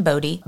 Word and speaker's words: Bodie: But he spelt it B-O-D-E-Y, which kind of looks like body. And some people did spell it B-O-D-Y also, so it really looks like body. But it later became spelt B-O-D-I-E due to an accidental misspelling, but people preferred it because it ended up being just pronounced more correctly, so it was fine.